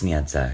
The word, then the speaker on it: Sniedza: It's Sniedza, because I was like, how you do that, girl? And he was like, Sniedza.